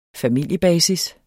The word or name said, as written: familiebasis